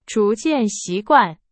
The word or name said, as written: 逐渐